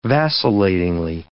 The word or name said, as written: vacillatingly